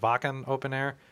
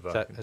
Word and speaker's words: Wacken Open Air: Is it Wacken Open Air? – Yeah, Wacken.